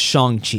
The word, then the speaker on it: Shang-Chi: And this has got Variety releasing a article that says that Shang-Chi, by the way, I think it's pronounced Shang-Chi. – Shang-Chi, yes.